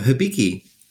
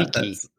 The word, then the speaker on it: Hibiki: So tell us a little bit about, I was reading on your Twitter today, something about, is it Hibiki whiskey? Or how do you pronounce it? – Oh, Hibiki. Yeah. Hibiki.